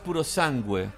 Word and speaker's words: Purosangue: Ahora, Mario, lo importante es cuánto va a costar el puro sangre. En verdad se llama puro, sangue, porque está en italiano.